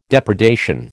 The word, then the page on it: depredation: American English